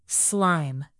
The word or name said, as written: slime